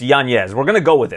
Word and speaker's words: Yanez: And for him to honor his father, I think we should honor his father by saying it properly, saying Yanez. – Fair enough. – For me, at least for here on out, it's Yanez.